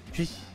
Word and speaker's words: Twitch: Es como Switch, es rígido, pero con T. – Como que no tengo los músculos de la lengua para eso, weón. – Twitch.